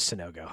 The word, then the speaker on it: Sanogo: It is Sanogo.